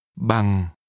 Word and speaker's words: bằng: Bằng bằng bằng đô la. The part meaning in or measured in is pronounced with a low falling tone.